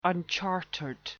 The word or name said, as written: unchartered